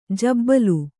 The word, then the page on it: ಜಬ್ಬಲು: jabbau